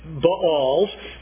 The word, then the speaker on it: Baals: He raised up altars for the Baals, and that is, according to our Hebrew guide in Israel, the proper pronunciation there, Baals, and made wooden images.